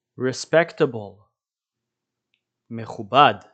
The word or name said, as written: respectable